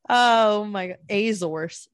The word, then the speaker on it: Azores: I said that wrong. – Azores. I just learned how to say it like literally 2 days ago. – It's not Azores. – I thought it was Azores, no, which come on. Sounds cooler, but I guess it's Azores.